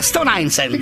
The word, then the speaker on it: Stone Island: Questi gym della Stone Islands. – Stone Island. – Ma perché scrivono? Scrivono come si legge. – Si chiama così, Stone Island.